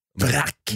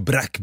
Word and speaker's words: Braque: Nej, B-R-A-Q-U-E. Jag tror det är Brack. Brack blir det, okej.